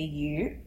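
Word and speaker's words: 鱼: So for example, a fish in Chinese, you say yu, that's how you say it, but yu is also another word for prosperity.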